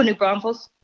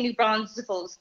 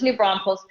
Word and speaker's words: New Braunfels: Oh, New Braunfels. – New Braunfels. Okay. Yes. – Yeah, everybody wants to say New Braunfels, but yeah, it's New Braunfels.